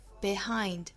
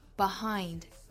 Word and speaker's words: behind: 我们听到过老外把这个词读成 behind，也听到过它被发成 behind，所以两种发音都是对的哈。